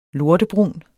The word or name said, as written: lortebrun